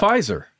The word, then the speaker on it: Pfizer: From Pfizer. – Jesus. I also started with a P, but the P is silent.